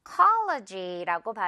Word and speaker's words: psychology: 이건 너무나 어려워 보이긴 하지만 PSY는 P가 묵음이다. 이거 포인트 하나만 기억하라고 가져왔습니다. PSY 있을 때는 P 발음되지 않아요. 그래서 psy라고 발음이 되죠. psychology라고 발음이 되는데 심리학이라는 뜻이에요.